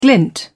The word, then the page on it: glint: British English